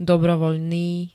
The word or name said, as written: dobrovoľný